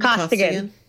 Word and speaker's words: Costigan: am I saying that right? Costigan? – Costigan. – I'm saying it in the main accent that he... Costigan. – Yeah, you are.